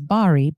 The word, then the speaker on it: Bari: It's spelled Bari. So some people may pronounce it as Bari, but it's pronounced Bari.